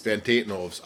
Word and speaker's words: Van Tatenhove: Who were the first, and if I mispronounce this name, please correct me, but Van Tavenhoves? – Van Tatenhoves. I'm Van a Van Tatenhove.